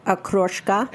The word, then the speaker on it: okroshka: pronounce these for me. It's a cold Russian soup, okroshka. – Yes, okroshka.